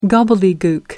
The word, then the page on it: gobbledygook: British English